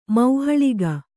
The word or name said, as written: ಮೌಹಳಿಗ